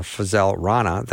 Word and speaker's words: Fazale Rana: So I have to figure out when I say Fuzz Rana or Fazale Rana that it's not a name I say all the time.